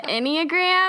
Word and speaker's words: Enneagram: Well, and I even remember back then asking someone about it, and I mispronounced it. I said, like, have you heard of the Enneagram? And they said the Enneagram. And I was like, oh, yeah, that must be it. That's how you say it.